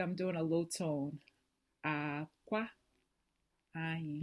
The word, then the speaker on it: akwa: And we know that clothes in Igbo is akwa. – For clothes. – But then eggs is, yeah, high, low. And then egg is low, high, but they're both spelled the same.